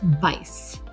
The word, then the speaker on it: Weiss: It's spelled like Weiss, W-E-I-S, but it's pronounced Weiss. That's the German pronunciation.